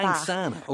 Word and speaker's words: Angsana: Angsana, okay, Angsana, that's how it's pronounced.